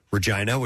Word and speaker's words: Regina: Regina, which is pronounced in the way that rhymes with a certain private part, is located about 100 miles north of the U.S.